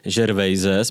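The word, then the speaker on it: Gervais: že nejnovější stand-up Rickyho Gervaise, jsme se shodli, že se vyslovuje takto na základě toho, že jsme si ho pustili, a že nejen, že není vtipný, ale je vlastně vyloženě trapný.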